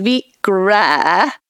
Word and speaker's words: Vikre: It is Vikre. It's a Norwegian name.